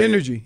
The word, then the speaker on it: NRG: What am I supposed to pronounce it? Energy. – Yeah, that's the company. It's NRG. – But is it? But do they? – Energy.